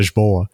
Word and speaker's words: Lisboa: And in the past month, I went to Lisbon in Portugal, or as the locals call it, Lisboa. – Oh, really? – As we recently learned. Lisboa. Yeah, that's why they spell it with like an A at the end instead of an N.